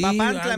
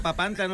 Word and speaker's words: Papantla: Sí, Papantla, mijo, Papantla, no Papantla. Es que opina y no sabe ni pronunciar las palabras. – ¿Pero cómo es? – Los voladores de Papantla.